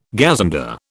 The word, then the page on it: gazunder: British English